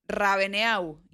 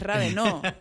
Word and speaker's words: Raveneau: El apellido de mi papá es Raveneau, pero se escribe Raveneau. E-A-U en francés se pronuncia como O. Entonces, hay personas que le dicen Raveneau. Y él dice, no, es Raveneau.